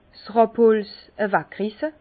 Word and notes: 's Rabholz aweg risse: Bas-Rhin; Herrlisheim

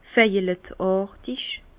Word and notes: veilleletàrtig: Bas-Rhin; Herrlisheim

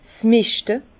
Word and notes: 's Meschte: Bas Rhin; Herrlisheim

